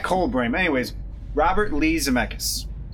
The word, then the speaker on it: Zemeckis: Written and directed by Lee Zemeck. Zemeckis, sorry.